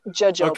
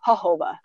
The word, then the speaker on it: jojoba: yes, most people know it as jojoba. – Okay, that's okay. But it's actually pronounced jojoba.